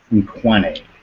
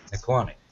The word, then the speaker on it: Nkwane: And on the back of the card, written in Ellis's handwriting, not that you know that, is the name Silas Nkwane, and I will spell that for you. N apostrophe K-W-A-N-E. – Nkwane.